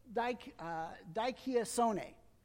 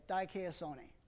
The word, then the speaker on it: dikaiosune: Well, it's this word, dikaiosune, okay? And that's the Greek. That's how you say it, dikaiosune.